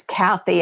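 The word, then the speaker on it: Cathy: sure my email well my website is kathy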